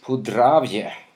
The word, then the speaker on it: Podravje: Och en av de största där man tillverkar mest vin är den som är i nordöstra eller norra delen av Slovenien som heter Podravje. Ja, don't blame me, men det är så det uttalas i alla fall.